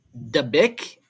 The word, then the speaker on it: Dabik: Dabik, and it's pronounced like big dick, but swap the B's and the D's and drop the G, so always remember that while reading this.